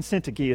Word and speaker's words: Syntyche: And it's obvious that these two ladies, Euodia and Syntyche is how I pronounce their names. We'll find out in heaven if that was right or not.